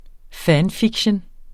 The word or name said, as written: fanfiction